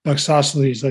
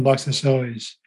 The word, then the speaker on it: Loxosceles: Also, I think I'd like to step back a bit, the, the pronunciation of, of the spiders, Loxosceles, like isosceles, not Loxosceles. Oh, well, I learned something. – Thanks. Well, Loxosceles, Loxos is crooked, and then Celes is legs, like isosceles triangle has two equal legs.